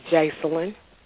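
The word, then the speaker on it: Jasalyn: It's Jaceline. – Okay, spell that for me. – J-A-S. – Uh-huh. – A-L-Y-N.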